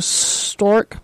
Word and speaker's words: Storz: This company, and I'll spell it for you, Stork Medical.